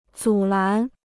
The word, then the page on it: 阻拦: zǔ lán